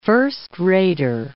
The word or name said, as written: first-rater